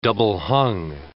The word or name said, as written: double-hung